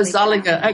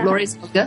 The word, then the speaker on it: Zulliger: And I'm um, talking to Lori Zulliger. Am I pronouncing that correctly? Well, it's probably with your own accent. That's correct. Zolliger is how it's typically. Oh, Zolliger. Okay, mm-hmm. okay. Um, Lori Zolliger, who lives in Alaska, and she homeschools her daughter.